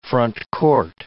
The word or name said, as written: frontcourt